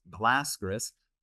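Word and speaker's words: Baylisascaris: And the most common causes of it are zoonotic ascarids, such as Toxocaracanus, Toxocara cati, and the raccoon ascarid, Balus ascaris, sometimes pronounced Blascaris, Procidonis.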